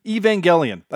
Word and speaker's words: Evangelion: The G is a soft G, like GIF and JIF. Evangelion.